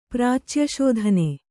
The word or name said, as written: ಪ್ರಾಚ್ಯಶೋಧನೆ